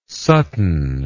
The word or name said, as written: Sutton